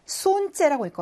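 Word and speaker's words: 손째 (солнце): 그다음 태양이라는 단어는요. 손째라고 읽거든요. 어떤 발음이 묵음이냐면 첫 번째 자음인 L이 묵음이에요. 손째.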